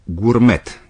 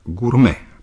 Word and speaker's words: gourmet: Dacă îl văd pe un produs alimentar sau dacă este numele unui restaurant, românii probabil îl pronunță cam cum se scrie, gourmet, deși în limba franceză nu se aude t-ul final. Așa că se pronunță gourmet în limba franceză.